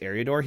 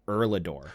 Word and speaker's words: Eriador: The narrator, I don't know if he just got his copy all in all caps or something, but he doesn't pronounce Eriador as Eriador. He pronounced it as Erlador.